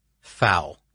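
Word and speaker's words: Pfau: and I'm going to pronounce the name here, Fau. It's P-F-A-U.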